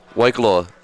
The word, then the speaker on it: Waikoloa: Yeah, Waikoloa.